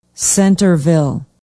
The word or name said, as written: centerville